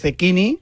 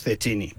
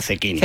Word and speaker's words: Zecchini: Sí, pero bueno, en teoría es Zecchini. Lo que pasa es que nosotros, como el más castizo, decimos Zecchini, pero realmente hay que pronunciar Zecchini.